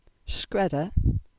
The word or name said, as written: Scredda